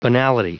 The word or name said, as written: banality